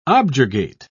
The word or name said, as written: objurgate